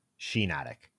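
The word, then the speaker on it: Shiinotic: I gotta correct you here before we get too deep. Shinotic, like shiitake. – Oh, really? Yeah. Oh, I thought it was spelled the same way. Two eyes.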